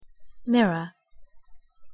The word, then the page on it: mirror: English